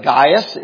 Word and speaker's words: Gaius: The guy's name was Gaius Popillus. And that's a poor pronunciation of that name, I'll just tell you that. But Gaius is right.